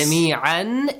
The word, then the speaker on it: جميعاً: جميعاً. Why did you say جميعا? It's جميعاً. – Because when we pause, the tanween changes into fatha.